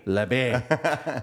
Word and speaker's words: Labbe: Labbe.